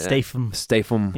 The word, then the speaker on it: Statham: Statham.